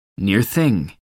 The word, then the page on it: near thing: American English